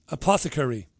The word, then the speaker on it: apothecary: They have a low-cost herbal apothecary. I can never pronounce that word. Apothecary. Pronounce that for me, Christy. How do you pronounce that? – You did. – Did I pronounce it properly? Yeah, yeah.